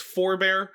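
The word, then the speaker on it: Pfhorbear: My Bungie name is Forbear. I know it's spelt funny. It's P F H O R B E A R hashtag or pound six one one zero.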